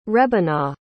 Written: rebinah

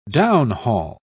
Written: downhaul

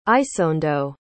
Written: isondo

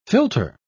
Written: filter

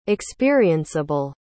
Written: experienceable

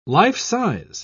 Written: life-size

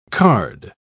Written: card

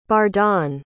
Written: bardon

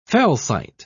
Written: felsite

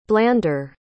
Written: blander

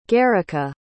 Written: gerika

